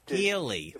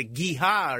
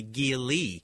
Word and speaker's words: Geely: I said a Geely or something, and it's really Geely.